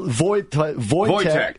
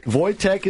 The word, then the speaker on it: Wojtek: Well, Wojtek. Wojtek is his name. You spell that W-O-J-T-E-K.